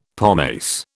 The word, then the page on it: pomace: British English